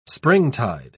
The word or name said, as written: springtide